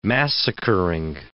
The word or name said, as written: massacring